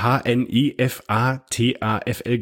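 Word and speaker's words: Hnefatafl: Und zwar habe ich ein wunderschönes Hannover-Tafel. Den Namen kann ich bis heute noch nicht aussprechen. Das ist wird Hanifatafl gesprochen.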